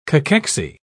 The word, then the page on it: cachexy: British English